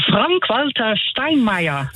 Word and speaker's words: Frank-Walter Steinmeier: Dat is Frank-Walter Steinmeier. – Ja, kan ze nog het Duitse uitspreken voor mij? – De Frank-Walter Steinmeier. – Vielen dank. – Ja, gedaan. – Und richtig.